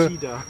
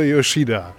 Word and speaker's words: Yoshida: Wir waren gerade in Nippon Connection im Film Thicker Than Water von Keisuke Yoshido. Es ist ein Film über, jetzt habe ich wieder falsch gesagt, das ist Keisuke. – Yoshida. – Ach, ein Film von Keisuke Yoshida.